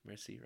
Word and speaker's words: Merci: But Mercy, Merci, right? – Merci beaucoup.